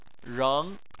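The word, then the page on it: reng: tone 2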